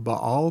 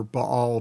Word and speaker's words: Baal: Apparently, the ancient Semitic languages didn't use diphthongs. So two letters together, you pronounce both letter. I have been told, I am not an expert in ancient Semitic languages, but I am told it's Baal and not Baal.